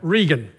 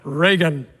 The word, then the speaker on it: Reagan: Just President Ronald Reagan, actually an Irish name and pronounced back there, Regan. But you know it as Reagan.